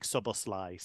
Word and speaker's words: Szoboszlai: And it, apparently in Hungarian, it's not massively different. There's just a slight difference on how you say the final syllable. But he has said in the Bundesliga, Dominik Szoboszlai.